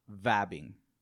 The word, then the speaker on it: vabbing: And it's called vabbing. Have you heard of it? – No. – V-A-B-B-I-N-G. Okay. – Never heard of it. – Vabbing. Now, it's vabbing.